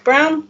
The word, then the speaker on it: Braun: If you've heard my name before, it's probably in association with Virginia Brown, although it's spelt Braun, it's pronounced Brown.